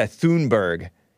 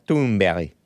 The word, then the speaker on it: Thunberg: He made a video post explaining how to pronounce Greta Thunberg, because a lot of you guys call her Greta Thunberg, but she's Swedish and it's pronounced Greta Thunberg, something like that.